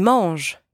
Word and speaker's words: mangent: Ils mangent. They eat. Masculine. Ils mangent. Elles mangent. They eat. Feminine. Elles mangent. And again, don't pronounce the ENT at the end.